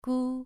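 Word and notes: gu: tone 2

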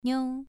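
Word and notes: niu: tone 2

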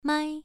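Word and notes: mai: tone 1

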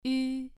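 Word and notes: yu: tone 2

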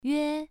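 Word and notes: yue: tone 1

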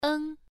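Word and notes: eng: tone 1

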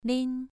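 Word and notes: lin: tone 2

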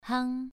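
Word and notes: hang: tone 2

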